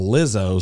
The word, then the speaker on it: Lizzo: She could tell you the current slang in a Lizzo song.